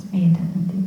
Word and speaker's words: Medhanandi: So my name is Medhanandi, but it's spelled differently.